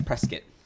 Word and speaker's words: Prescott: So when I said Prescott, he definitely informed me to always say it as Prescott. – Dude, I still say it. I still say Prescott, mainly because I don't care.